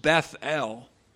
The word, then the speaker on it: Bethel: And that name is pronounced Bethel.